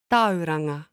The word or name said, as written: Tauranga